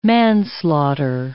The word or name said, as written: manslaughter